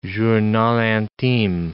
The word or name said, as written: journal intime